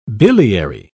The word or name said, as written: biliary